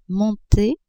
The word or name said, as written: monter